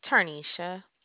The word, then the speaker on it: Tarnisha: Tarnisha. – Can you spell that for me? – T-A-R-N-I-S-H-A. – S-H-A.